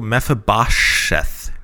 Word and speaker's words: Mephibosheth: The one that I was having great trouble pronouncing, M-E-P-H, so that's where the F comes in, I-B-O-S-H-E-T-H. – Mephibosheth? – It's like Mephibosheth. – You have to go Mephibosheth.